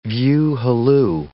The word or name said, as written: view halloo